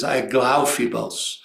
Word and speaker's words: Aglaophemos: And you finally gave me the right pronunciation, which is Aglaophemos. Not Aglaophemos, but Aglaophemos is like, yeah, it moves more like that.